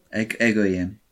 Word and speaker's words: Egoyan: Okay, next, we have Atom Egoyan for The Sweet Hereafter, Is it Egoyan? Is that how you pronounce it? – Egoyan. – Atom Egoyan. Okay.